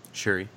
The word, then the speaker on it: Shuri: Shuri? Shuri, yeah.